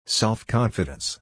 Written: self-confidence

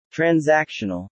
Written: transactional

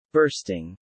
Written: bursting

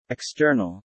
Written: external

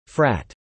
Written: frat

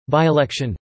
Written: by-election

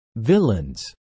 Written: villans

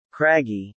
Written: craggy

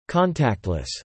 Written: contactless

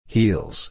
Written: heals

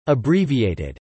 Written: abbreviated

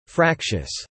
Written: fractious

